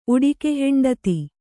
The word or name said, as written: ಉಡಿಕೆಹೆಂಡತಿ